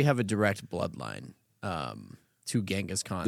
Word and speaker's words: Genghis: I think it's pronounced Genghis.